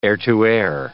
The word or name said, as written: air-to-air